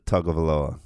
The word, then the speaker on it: Tagovailoa: Don't forget about the Tagovailoa from Maryland.